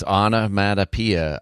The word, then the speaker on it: onomatopoeia: you have to because John already explained it's onomatopoeia, right? Is that how you pronounce it? – Yeah.